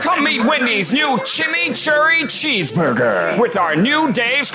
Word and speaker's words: chimichurri: Chimichurri. – That's how you pronounce it. Let it roll off your tongue.